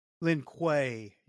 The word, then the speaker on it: Lin Kuei: Is that how you pronounce that? – I think he said for the Lin Kuei. – Lin Kuei. Yeah, that sounds a lot better.